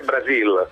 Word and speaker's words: Brasil: E quindi quando si trova di fronte a qualche giocatore brasiliano con questa particolarità, le L finali sono sempre I, è Brasil, non è Brasil.